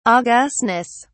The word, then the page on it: augustness: British English